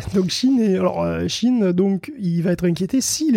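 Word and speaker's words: Shein: Ouais, il y en a un autre qui va être sérieusement inquiété par cette loi, c'est Chine. Remarquez. – Ah, dis donc, c'est fou, tu le prononces vachement bien.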